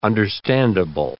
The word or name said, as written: understandable